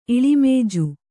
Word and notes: ಇಳಿಮೇಜು: kannada

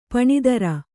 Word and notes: ಪೀನೋದರ: pīnōdara

